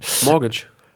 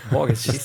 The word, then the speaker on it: mortgage: Mortgage. – Wie? – Mortgage. – Aha. – Mortgage. Das T ist still.